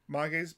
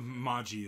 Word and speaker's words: Mage: The next day, after Nathan's daughter Mage's birthday, I want to pronounce it Mage, but I know it's wrong. Maji is how they were saying it in the show. – Maji. Mm-hmm. But it's spelled Mage. – It is. It's spelled Mage, but they say Maji.